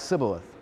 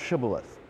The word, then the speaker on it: Shibboleth: And so in some regions, it would be pronounced Sibboleth. In other regions, it would be pronounced Shibboleth.